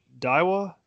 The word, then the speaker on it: Daiwa: Is it Daiwa? – Yeah.